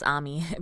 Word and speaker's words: Ami: So I don't know how many of you are familiar with the influencer Ami Song, but you might not know her name is pronounced Ami because it's spelled phonetically like Amy.